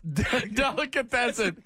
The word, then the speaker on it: delicatessen: Delicion or something? Like delicatessen? He's like Delicion or something. – You're trying to pronounce delicatessen. That was a disaster.